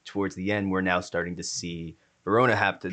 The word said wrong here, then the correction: Ramona: now almost, am I saying her name right? I'm saying Ravonna. Ramona. I'm saying V. Yeah, Ramona.